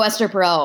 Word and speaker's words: Esther Perel: You guys did one a couple years ago with, and I'm going to say her name wrong, I think it's Esther Perel. – Oh, Esther Perel.